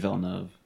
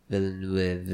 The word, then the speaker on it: Villeneuve: It's Denis Villeneuve. – Villeneuve. – It's probably not. – How do you pronounce it? – Denis. – It's Canadian. Villeneuve.